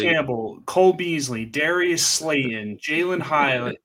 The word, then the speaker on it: Jalin Hyatt: And then if you go down a little bit, you also got the rookie um, Jalin Hyatt, I believe is how you pronounce it, yep.